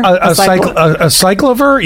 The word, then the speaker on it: Acyclovir: Acyclovir, yeah.